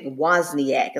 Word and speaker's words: Wozniak: Apple in 1976, Steve Jobs and Wozniak is how you pronounce his name.